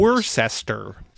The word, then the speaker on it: Worcester: So you only know that it's called Worcester if you ever been to Worcester. – For anybody that doesn't know about Massachusetts, it's spelled like Worcester, but it's called Worcester, as in W-O-O-S-T-E-R.